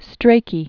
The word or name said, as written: Strachey, (Giles) Lytton